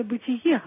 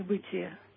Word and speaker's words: бытие: Вы знаете, я стесняюсь очень сделать это замечание, но мне кажется, произносится «бытие», а не «бытие».